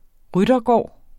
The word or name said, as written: ryttergård